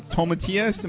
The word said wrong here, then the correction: Tomatillos: Am I saying it right? – Tomatillos.